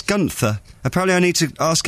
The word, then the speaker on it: Gunther: It's not Gunther, it's Gunther, as in Gunther, and then she puts a big duh in capitals.